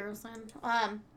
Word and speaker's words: Harrelson: Harrelson? You said it right. Harrelson.